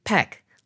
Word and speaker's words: Paik: People always ask me how to pronounce my last name because it's spelled P-A-I-K, so some people think it's Pike or Paik, but it's actually Peck, like the old actor Gregory Peck.